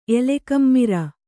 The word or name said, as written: ಎಲೆಕಮ್ಮಿರ